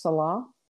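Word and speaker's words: Selah: And so he likes to put in these It's sometimes pronounced Salah in the modern.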